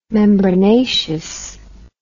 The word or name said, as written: membranaceous